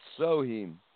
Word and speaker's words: Samhain: It's called Samhain, pronounced Soheim.